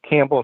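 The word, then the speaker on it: Kambel: Campbell is spelled K as in kangaroo, A as in apple, M as in Mary, B as in boy, E-L.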